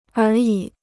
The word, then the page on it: 而已: ér yǐ